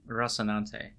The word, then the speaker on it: Rocinante: Rocinante.